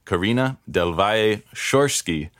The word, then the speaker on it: Carina Del Valle Schorske: I'm pleased to welcome the person responsible for all of these pieces and more, the writer and translator, Carina Del Valle Schorske.